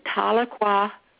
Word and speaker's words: Tahlequah: I live in Tahlequah, Oklahoma, and Tahlequah means two is enough.